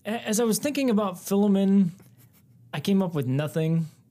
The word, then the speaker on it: Philemon: I say Philemon. – I say Philemon.